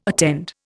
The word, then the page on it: attend: Indian